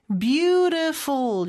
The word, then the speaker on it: beautiful: 그 다음에 beautiful인데 beautiful 이렇게 발음이 되죠. t는 모음 소리 사이에 껴서 리을처럼 발음이 돼요.